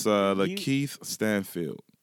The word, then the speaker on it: Lakeith Stanfield: It's Lakeith Stanfield.